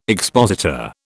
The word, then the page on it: expositor: British English